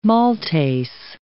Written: maltase

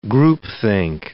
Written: groupthink

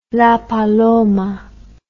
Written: La Paloma

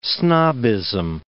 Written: snobbism

